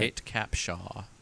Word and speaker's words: Kate Capshaw: Kate Capshaw, right? Am I saying that right? – I think so, yeah. – Suddenly it didn't sound right. – Kate Capshaw.